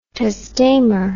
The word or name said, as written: testamur